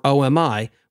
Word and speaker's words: OMI: Azure embeds a management agent in many services called Open Management Infrastructure, or OMI, which I insist is pronounced with three syllables, not two.